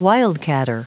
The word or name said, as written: wildcatter